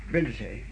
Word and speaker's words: Brindisi: Brindisi? – And was, yes, Brindisi, the Italians pronounce it.